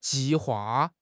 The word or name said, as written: ji4 hua4